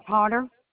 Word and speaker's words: Potter: Tina Potter. – Okay. Can you spell that last name? – P-O-T-T-E-R.